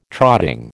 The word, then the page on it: trotting: American English